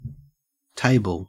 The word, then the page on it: table: Australian English